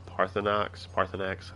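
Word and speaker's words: Paarthurnax: Going back to Skyrim and is it Parthenax, however you say his name? – Yep, Parthenax.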